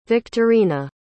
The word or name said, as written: victorina